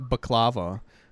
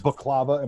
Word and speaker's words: baklava: How is baklava and baklava not the same fucking thing? It's spelled exactly. You're just putting the different emphasis on the wrong syllable.